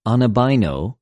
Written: ἀναβαινω

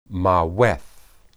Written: maweth

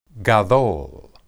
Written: gadol

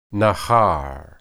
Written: nahar

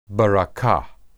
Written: berakah

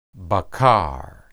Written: baqar

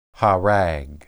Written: harag

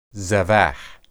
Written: zebah

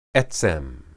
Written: etsem

